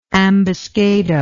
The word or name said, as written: ambuscado